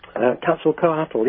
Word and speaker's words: Quetzalcoatl: I haven't heard you mention, and I'm going to pronounce the name, I'll get close to it, Quetzalcoatl? – Quetzalcoatl, yes.